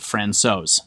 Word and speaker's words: Francouz: His last name is actually pronounced Francouz.